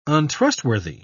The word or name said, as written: untrustworthy